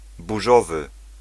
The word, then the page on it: stormy: /ˈstɔɹ.mi/